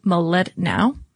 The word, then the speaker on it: Mlodinow: And there is a really interesting book, Leonard Medelanov. Excuse me. Let me try that again because I'm sure that at least his family would appreciate that I pronounced it correctly. But Leonard Mlodinow.